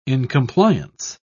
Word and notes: incompliance: British English

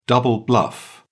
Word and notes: double bluff: British English

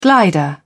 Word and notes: glider: British English

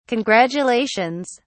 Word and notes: congratulations!: British English

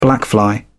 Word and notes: blackfly: British English

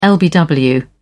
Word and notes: lbw: British English

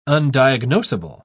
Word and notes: undiagnosable: British English

